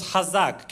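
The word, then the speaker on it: hazak (חזק): So the word strong in Hebrew sounds hazak.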